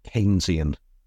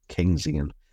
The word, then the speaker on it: Keynesian: I gave you the wrong pronunciation. It's actually Keynesian. I was, let's just say, on the move when you asked me. An open back unrounded vowel, Keynesian.